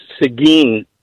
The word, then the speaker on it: Seguin: The little town is actually pronounced Seguin, Texas.